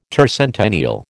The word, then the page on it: tercentennial: American English